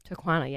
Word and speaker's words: Taquana: Taquana? – Taquana, yes. – Rhymes with marijuana.